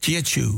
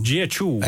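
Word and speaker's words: Jiechu (杰出): How was it again? I looked it up. – Jiechu. – Jiechu. Excellent.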